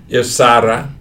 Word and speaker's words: Irsara: Irsara. – I'm trying my best to roll my R.